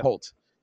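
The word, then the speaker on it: Hoult: So obviously you have Nick Cage and you have Nicholas, what's his last, is it Holt? – Holt. – Is that how you say it? – Yep. – Holt.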